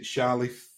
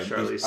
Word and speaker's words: Charlize: And I can't, a first name's a bit hard to pronounce for me, but is it Charlie Theron or Charlize Theron? – Oh, yeah, Charlize Theron.